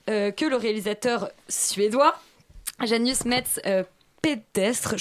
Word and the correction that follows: Pedersen: Je suis pas très sûr de la prononciation. Moi j'ai Pedersen.